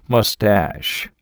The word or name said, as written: mustache